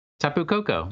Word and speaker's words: Tapu Koko: Tapu Koko? – Tapu Koko, yeah.